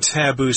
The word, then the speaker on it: taboo: Now, the idea of avoiding taboo subjects also looms heavily throughout the book. Now, here we have the word taboo. – Or taboo. – Taboo, yes, I believe the accent is on the second syllable.